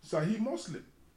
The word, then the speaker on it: Sahih Muslim: There is something in Islam that teaches about Sahih Muslim. If I don't pronounce it well, please forgive me. S-A-H-I-H. Sahih Muslim.